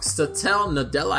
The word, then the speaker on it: Satya Nadella: At which point, Satya Nadella, I always say his name wrong, Satya Nadella, I think is the way you say his name, the CEO of Microsoft, picked up the phone real quick.